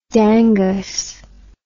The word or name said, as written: dangus